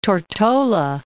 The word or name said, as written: Tortola